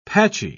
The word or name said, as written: patchy